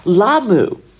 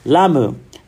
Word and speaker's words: Lamu: And every time I would say it at dinner last night, she would correct me very kindly. She's a mom. She's got a 7-year-old. You know how parents, like when their kids are mispronouncing things or using grammar incorrectly, the parent will repeat it back to them to make sure they get it right. And so she did it until Lamu started to roll off my tongue very easily.